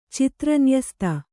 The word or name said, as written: ಚಿತ್ರನ್ಯಸ್ತ